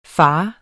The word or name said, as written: fars